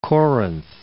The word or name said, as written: Corinth